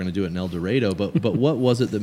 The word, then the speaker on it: El Dorado: So we are here in El Dorado, Arkansas, and now I want to first clarify that the pronunciation of that, I got it wrong the first time, so I'm going to make sure that for the rest of the time, it's El Dorado, and I'll get it right. – Correct. That's how it is pronounced down here.